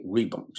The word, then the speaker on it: Reebok: My dictionary is American dictionary, and had I been looking at an Oxford English dictionary, it would have been R-H-E-B-O-K. And sometimes R-H-E-B-O-C-K. Nothing like the Reebok, just R-W-E-B-O-K. Two syllables, brilliant.